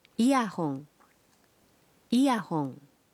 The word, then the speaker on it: イヤホン: Earphones are pronounced as イヤホンイヤホン.